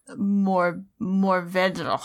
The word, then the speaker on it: Mourvèdre: That word is Mourvèdre. – Perfect. Nailed it. Beautiful pronunciation.